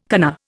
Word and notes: kana: Indian

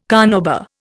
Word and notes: carnauba: Indian

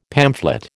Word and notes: pamphlet: American English

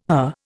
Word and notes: her: Indian

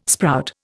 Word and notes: sprout: Indian

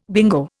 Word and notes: bingo: Indian

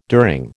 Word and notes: during: American English

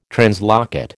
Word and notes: translocate: American English

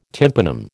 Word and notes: tympanum: American English

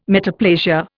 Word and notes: metaplasia: Indian

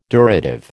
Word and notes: durative: American English

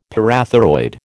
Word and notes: parathyroid: American English

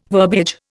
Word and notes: verbiage: Indian

